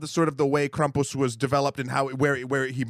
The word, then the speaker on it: Krampus: The myth and the legend behind Krampus, and I'm saying it like that because Christoph Waltz on the internet taught me that's how you say it.